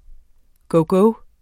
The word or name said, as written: gogo